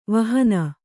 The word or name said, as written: ವಿಹೀನೆ